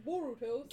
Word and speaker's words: Boruto: So the book is Naruto rhymes with Boruto, so we all know who this is gonna focus on.